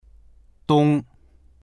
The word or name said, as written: dong1